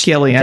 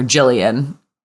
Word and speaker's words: Gillian: He's talking all about how much he likes Gillian with a hard G, which Gillian. – Jessa obviously ignores and calls her Jillian.